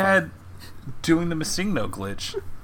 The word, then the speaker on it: Missingno: It's Missingno. As in missing number.